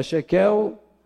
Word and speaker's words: shekel: Lá você usa o shekel, ou shekel, acho que é shekel, é shekel, shekel, shekel.